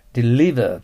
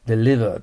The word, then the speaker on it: deliver: 次は、 deliver と発音します。 l はいいですね。 v は下唇を上の歯で半分ぐらい噛んで、 deliver です。